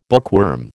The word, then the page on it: bookworm: American English